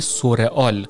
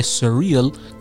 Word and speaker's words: surreal: با تلفظ سورئال که میگن فلان چیز خیلی سورئال بود در حالی که این تلفظ کاملا اشتباه هست یعنی در واقع تلفظ فارسیش نمیشه سورئال چون اگه سو باشه یعنی اینکه خیلی واقعی هست در حالی که در واقع معنای سریل کاملا برعکسش هست